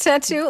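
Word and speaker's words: Benetatou: Benetatu oder wie Mandy Capristo. Man muss es einfach so aussprechen.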